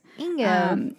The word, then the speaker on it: Ingo: So this dude is named Inyo Swan. I don't know how to say his first name. It's Ingo. So I don't know if it's like Ingo or like Inyo something. Anyways. – Ingo.